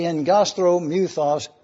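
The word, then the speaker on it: engastrimythos: If you're anxious to write these letters down, here you have them: Aggastromuthos. I realize that's a big word, and you'll remember that when you have two G's in the Greek, it's pronounced NG, so it's the engastromuthos demon.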